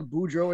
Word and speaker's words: Boudreaux: It's not like Boudreaux or Thibodeau where there's a bunch of silent E's, A's, U's, X's, whatever you want to call them.